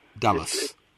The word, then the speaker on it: Dulles: D-U-L-L-E-S. – Yeah, but the pronunciation, I was corrected in the way I was saying it. It comes from the word Douglas, apparently. – All right. – The great-great-grandfather of Alan was working in the East Indies years ago, mm-hmm. Indonesia, that is, mm-hmm. when it was Dutch, and the Dutch G, the glottal G, when they pronounced Douglas, it came out Dallas, without the G got swallowed down the throat. And Douglas became Dulles.